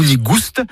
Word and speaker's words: nigoust: Nigou nigoust, avec prononciation du S originel que l'on retrouve dans le provençal gustus, goûteux, et qui a été remplacé depuis par l'accent circonflexe dans notre orthographe moderne.